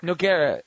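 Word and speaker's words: Nogueira: Lucas Nori, how do you pronounce his last name? – Noguera.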